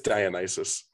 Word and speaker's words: Dionysus: It's Dionysus.